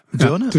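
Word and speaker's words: Deurne: Dürne. Richtig ausgesprochen? Ja.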